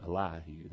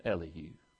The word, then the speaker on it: Elihu: Two pronunciations of his name. Man, he's got him Elihu. That's good. Some call him Elihu. That's good, too. So whichever one you want to use, both are proper.